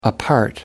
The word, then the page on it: apart: American English